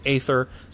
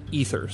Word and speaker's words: aether: Like some people pronounce it aether, some pronounce it ether.